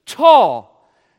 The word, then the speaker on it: Thal: Tal, T-H-A-L with a silent H, tall.